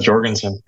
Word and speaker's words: Jorgenson: So, Matteo Jorgenson or Jorgenson? Hard J, soft J? Or maybe I butchered it entirely. – I don't know. You got it, Jorgenson. First try. – Yeah, right on. – That's the American way to say it.